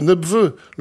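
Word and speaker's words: Lenepveu: Nebveu, avec ce P qu'on prononce là-bas.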